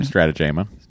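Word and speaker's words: Strategema: He's saying, meanwhile, the B story is about Data and the Stratagemo game. – Mm-hmm. – Was I pronouncing that correctly? – Stratagemo. – Jemo.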